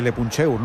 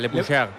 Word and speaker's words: Le Puncheur: No, lo has dicho bien, lo has dicho bien. – No, pero se dirá en francés. – Se puede mejorar, Le Puncheur.